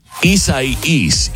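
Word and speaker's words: Isaias: So I believe it's pronounced Isai-us, this is a hurricane.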